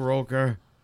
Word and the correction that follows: Rooker: Rooker. – I keep saying Roker.